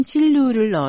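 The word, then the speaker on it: 실루 (丝路): 20m 크기의 이 공룡은 고대 중국의 무역로 실크로드의 중국 발음인 실루를 넣어서 실루티탄 시넨시스, 다른 하나는 발굴 지역인 신장 지명을 포함한 하미티탄 신장엔시스로 이름 붙였습니다.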